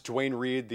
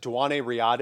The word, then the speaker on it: Duane Reade: One is that to this day, my wife and I pronounce Duane Reade, the drugstore in Manhattan. We pronounce it Duane Riade because one of her friends walked by it and was like, oh, there's Duane Riade.